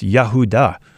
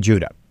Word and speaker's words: Yehudah: In Hebrew, it's pronounced Yehudah, but we say Judah, and it simply means praise.